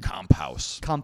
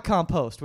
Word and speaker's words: Kamphaus: Which is actually Alex Kamphaus. Is that how you say it? Oh, it sounds very German. – Kamphaus. – It's not compost, which I'm sure he's had that before.